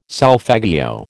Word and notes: solfeggio: American English

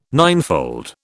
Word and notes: ninefold: British English

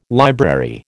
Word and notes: library: American English

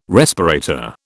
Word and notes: respirator: British English

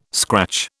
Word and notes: scratch: British English

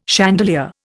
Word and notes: chandelier: Indian

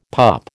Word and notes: pup: American English

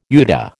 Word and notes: UDA: American English

